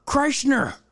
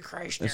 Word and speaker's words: Kreischer: Bert fucking Kreischer. Have you seen Bert him say Krishna? His name wrong on WWE and shit? – Yeah. – Bert fucking Kreischer.